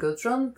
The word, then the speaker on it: Guðrun: The name is difficult to pronounce in English. It's Gudrun and Gudrun. It's spelled with the Faroese letter et, is silent. So internationally, typically people will say Gudrun and Gudrun, but actually it's Gudrun and Gudrun.